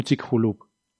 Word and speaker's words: Chicxulub: Pues, y, creo, que la, pronunciación, la X, es como la J. Entonces creo que es como Chicxulub.